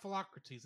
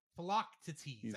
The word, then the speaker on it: Philoctetes: Yeah, sorry, it's not Philocrates, it's Philoctetes. I say it wrong every time.